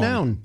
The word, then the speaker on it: McNown: McNown. You know, I always say, because it's M-C-N-O-W-N, McNown.